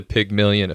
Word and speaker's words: Pygmalion: Yeah, the Pygmalion effect.